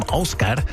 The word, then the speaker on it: Ásgeir: Gleich von Ásgeir. Der Mann kommt aus Island, deswegen wird er auch so ausgesprochen, Ásgeir.